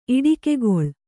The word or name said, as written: ಇಟ್ಟಿಗೆಗೊಳ್